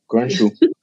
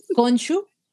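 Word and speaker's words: Khonshu: O sea, en verdad queremos ver mucho más a Gonchu Conchu, ¿cómo se pronuncia? – Conchu. – Conchu. – Conchu con sh. Con sh. Ajá, con sh.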